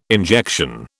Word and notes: injection: British English

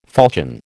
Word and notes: falchion: American English

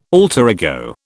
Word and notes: alter ego: British English

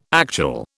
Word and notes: actual: British English

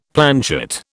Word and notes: planchet: British English

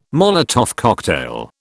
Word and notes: Molotov cocktail: British English

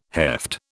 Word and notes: heft: British English

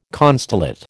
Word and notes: constellate: American English

